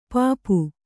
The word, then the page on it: ಪೆಪ್ಪು: peppu